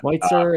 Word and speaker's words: Schweitzer: because Schweitzer is somebody who, like, I think I'm pronouncing that right. Schweitzer is very German. – Schweitzer, you lived in Germany, man. You got it.